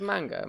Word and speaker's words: manga: The Japanese pronunciation is a lot like the romance languages, so it's A-E-I-O-U. – So it's, well, that like didn't manga.